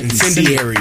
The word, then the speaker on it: incendiary: With great joy, another incinerary performance, gentlemen. You are on an 8-day heater. – Wow. Yeah. Thank you. – Incinerary. Did I say that right? – Incendiary. – Incendiary.